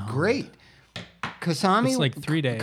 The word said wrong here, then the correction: Kamasi: Kamasi Washington, I never say his name right.